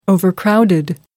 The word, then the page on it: overcrowded: American English